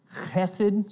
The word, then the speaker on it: chesed: It's the word hesed. You got to get some guttural going there. Chesed, something like that.